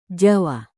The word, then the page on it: jawa: Indonesian